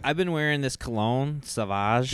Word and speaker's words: Sauvage: No, Sauvage.